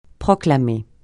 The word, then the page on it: proclamer: francais